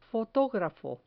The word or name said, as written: fotografo